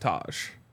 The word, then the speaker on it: Tyge: And as I've told you three times in the last 2 days, I believe it's pronounced Taj. – Taj, okay. – the spelling will throw you off.